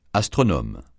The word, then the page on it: astronome: francais